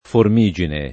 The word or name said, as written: Formigine